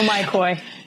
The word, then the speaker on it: myCOI: First off, for those not familiar with your company's name, it's pronounced myCOI and not myCOI. You're not involved with big fancy goldfish, correct? – I love it. Yep, you're correct. No myCOI.